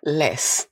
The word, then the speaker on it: Beyoncé: Läs Beyoncés. det uttalas Beyoncé.